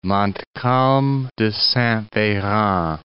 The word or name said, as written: Montcalm de Saint-Veran